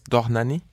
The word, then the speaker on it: Dohnányi: That's Dohnányi.